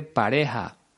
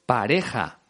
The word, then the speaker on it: pareja: Ricky Martin dice pareja, con el sonido de la J muy suavizado. Yo digo pareja, pero en Andalucía lo pronuncian como el cantante, pareja.